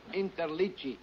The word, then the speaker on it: Interliggi: My name is Joseph Interligi. I-N-T-E-R-L-I-G-G-I.